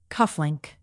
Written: cufflink

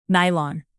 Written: nylon

